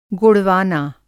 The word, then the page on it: गोड़वाना: Hindi